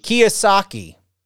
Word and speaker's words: Kiyosaki: Is that Kiyosaki? – Kiyosaki.